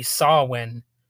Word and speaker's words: Samhain: But I know I know the proper pronunciation is supposed to be Sawin or something like that. You spell it S A M H A I N, and most people will say it that way, obviously. But if you're if you're a language nerd and if you followed horror movies and I guess if you're into the uh, religious aspects of it, you know it's pronounced differently than that.